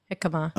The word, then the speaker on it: jicama: served in yamaka cucumber. Did I say that right? – No, jicama. – Oh, jicama, jicama. hickama Jicama cucumber, tortilla chips, chipotle salsa, and tomatilla salsa contain seeds, y'all.